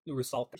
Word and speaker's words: Rusalka: Rusalka. – Rusalka. – Like Rus. Rus as in Russia.